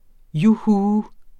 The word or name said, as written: juhu